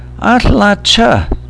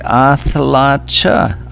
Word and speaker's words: atlacha: Atlacha, atlacha, or atlachid to a woman, and it has that tl sound in it, the boogeyman of Quileute pronunciation, the barred L, tl. Atlacha, atlachid. The school kids often say athlacha, athlacha, and one can understand that, athlacha, athlachid.